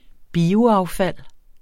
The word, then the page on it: bioaffald: [ ˈbiːo- ]